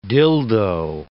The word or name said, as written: dildo